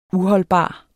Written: UHOLDBART

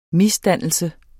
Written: MISDANNELSEN

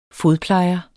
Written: fodplejer

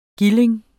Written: gilding

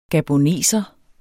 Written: gaboneser

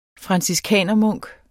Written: franciskanermunk

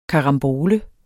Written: KARAMBOLE

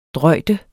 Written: drøjde

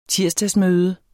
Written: tirsdagsmøde

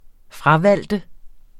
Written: fravælge

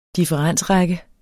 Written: differensrække

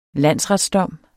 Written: landsretsdom

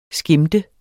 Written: skimte

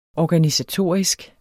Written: organisatorisk

